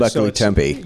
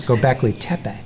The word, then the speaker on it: Göbekli Tepe: Göbekli Tepe. – Yeah, thank you. – It's Göbekli Tepe. – Göbekli. – It's an Tepe. Yeah. – See, everybody mispronounces it.